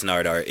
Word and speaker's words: Gnardart: Yeah, it's Nard Art, yeah.